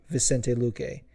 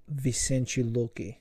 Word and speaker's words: Vicente Luque: Now, if you don't say it with a fucking super on point Portuguese accent like me, you might say Vicente Luque. And you wouldn't be wrong. But Vicente Luque is how it's supposed to be pronounced.